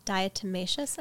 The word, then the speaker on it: diatomaceous: Have you heard of Diametrius earth? – Yes. Diatomaceous earth? – Yes.